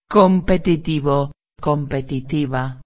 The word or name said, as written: competitivo a